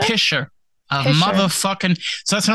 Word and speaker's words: pisher: Pisher is spelled P-I-S-H-E-R. Pisher.